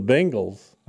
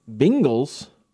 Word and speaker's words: Bengals: You said Bengals. – Bengals. – You had a real long E there.